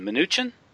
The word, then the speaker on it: Mnuchin: You mentioned Goldman Sachs and his appointment or his pick is this guy, Steven Mnuchin.